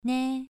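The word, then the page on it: nei: tone 2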